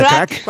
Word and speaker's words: craic: Con lo cual ahí podremos, Sharon, decirte a ti y al resto de componentes de The Kors, what's the craic? Creo que lo he dicho bien. – What's the crack? – Eso, what's the crack? Que es como el how are you, pero en irlandés, ¿vale? – What's the crack?